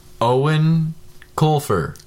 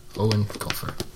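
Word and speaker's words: Eoin Colfer: Owen Colfer. – Owen Colfer.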